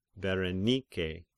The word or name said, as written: Berenike